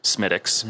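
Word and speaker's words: Smithwick's: It's spelt Smithwicks, mm-hmm. but it's pronounced Smithwicks.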